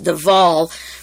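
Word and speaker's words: de Wohl: And William Stevenson sent out a man named Louis de Wahl, D-E, it's pronounced de Wahl, but it's spelled W-H-L, who came to the United States in 1941.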